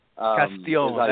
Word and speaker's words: Castillon: Is it Ramon Castillon? Did I say that okay? – It was close. I got to give you major kudos. It's Ramon the right way is Ramon Castillon. – Castillon.